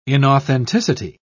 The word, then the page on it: inauthenticity: British English